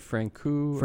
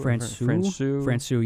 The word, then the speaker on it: Francouz: Played a little bit harder in front of Pavel Francouz, or Francouz. I'm not sure which one, how you say. We need Davey, the pronunciation master, on. – I've heard Francouz. – Francouz, yeah, that's it.